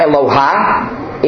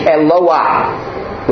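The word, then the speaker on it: Eloah (אלוה): It's not Eloha, it's Eloah, with the H sound at the end.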